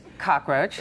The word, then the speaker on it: cockroach: I said cockroach. – I know, it's because it's cucaracha in Spanish. So you go cockroach. – Yeah, the extra syllable. – But it's cockroach.